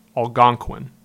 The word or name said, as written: Algonquin